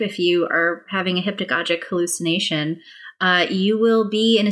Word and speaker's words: hypnagogic: Your topic here is, I'm not sure how to pronounce this word, hypnagogic, yes.